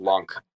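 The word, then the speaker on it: LUNC: I don't really know what the official pronunciation is, but I, it's L U N C, so I just think of it as lunk.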